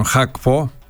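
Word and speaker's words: Gakpo: Λοιπόν, τώρα στα μεταγραφικά τα διεθνή, η City αφού δεν βρήκε την άκρη με τον Χακπό, όπως σωστά προφέρεται ο Ολλανδός δεξιός εξτρέμ της Λίβερπουλ, πάει να πάρει τον Διαγέ με το ζόρι, τρομερό αυτό, να πάρουν παίκτη από την Everton.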